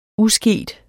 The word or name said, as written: usket